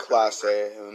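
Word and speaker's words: Clase: And Cleveland's closer, Claus, is a very, very good Class A, Emmanuel Class A. – Class A. I said it wrong.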